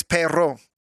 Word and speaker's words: perro: I think you got to roll your R's on that. I think it's perro.